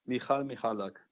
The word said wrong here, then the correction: Michał: Alexander Supertramp sagt, der Vorname wird Michał ausgesprochen. Michał Michalak.